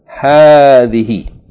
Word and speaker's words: هذه: Okay, this word هذه is pronounced as though there's an alif after the first letter ha. Ha fatta alif. هذه. Okay, it's elongated. The alif is not written, but you must pronounce it, okay, as though it is there. It is pronounced هذه, but the alif is omitted in writing.